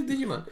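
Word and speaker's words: Djimon: Actually, I think it is Digimon. – That's how you say it. – Digimon Hansu. – At first, like, people would say his name is Digimon. I'm like, damn, that's real fucking disrespectful. But then it's like, it actually is Digimon.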